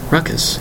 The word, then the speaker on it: raucous: The word is ruckus. What's all this ruckus we hear out there? What's all this ruckus I hear downstairs? The word is ruckus. R-A-U-C-O-U-S. An interesting word. Two distinct syllables.